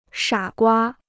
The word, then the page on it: 傻瓜: shǎ guā